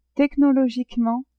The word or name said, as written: technologiquement